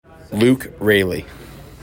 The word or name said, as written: Luke Raley